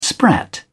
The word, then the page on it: sprat: British English